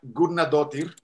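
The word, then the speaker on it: Guðnadóttir: Eh, bueno, según yo se pronuncia Gurnadotir,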